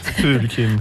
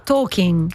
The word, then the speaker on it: Tolkien: Non lo chiamiamo Tolkien, in realtà si pronuncierebbe, pronuncialo tu. – Tolkien. – No, Tolkien sarebbe.